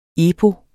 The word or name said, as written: epos